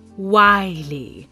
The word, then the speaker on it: wily: You can't fool me with your willy ways. – It's pronounced wily. While, but an adjective.